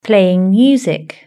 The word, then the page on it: playing music: British English